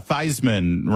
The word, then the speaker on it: Theismann: He should do what Joe Theismann did when he said, oh, yeah, Theismann rhymes with Heisman.